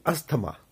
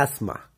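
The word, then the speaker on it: asthma: पर इसका भी इंग्लिश में एक प्रोनाउंसिएशन है और तब हम इसको बोलते हैं एस्मा। क्या बोलते हैं? एस्मा, यानी कि था, था की साउंड इसके अंदर नहीं बनेगी।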